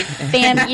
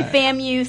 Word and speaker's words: FAMU: But, no, for sure, like, whenever I went, I didn't know if I'm supposed to say FAMU or FAMU. I was like, what should I do? Like, no, say FAMU. Yeah. Say FAMU. Say it like that.